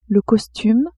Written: costume

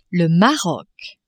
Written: Maroc

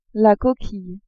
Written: coquille

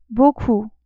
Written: beaucoup

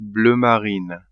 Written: bleu marine

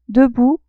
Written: debout

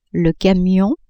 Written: camion

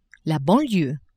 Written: banlieue

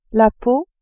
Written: peau

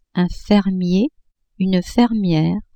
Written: fermier, fermière

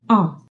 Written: a circonflexe

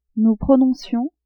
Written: nous prononcions

